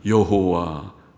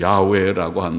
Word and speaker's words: 여호와: 여호와. 어떤 학자들은 야훼라고 한다고 발음하지만은 그러나 그것도 정확한 것 같지 않고 전통적으로는 여호와, 발음교를 여호와, 아도나이라는 말에 발음교를 붙였다고 학자들은 말하지만은 여호와, 그것은 익숙한 우리가 하나님의 명칭이 되었습니다.